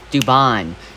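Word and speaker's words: Dubón: It's Altuve, Bregman, Alvarez, Tucker, Abreu, McCormick, Diaz, Pena, and Dubin. Dubon? Dubon. I always say his last name wrong.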